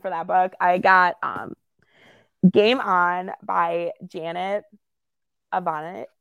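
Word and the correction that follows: Evanovich: Oh, I'm still going to screw it up, and I like phonetically wrote it out. Ivanovich, okay.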